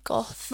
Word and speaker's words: Gothel: So, right, the first one is, you know, is it Gothel? Is that how you say her name? Gothel.